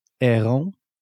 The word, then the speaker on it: Heron: Heron is the pronunciation. It's a bird, not a plane.